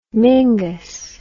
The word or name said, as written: mingus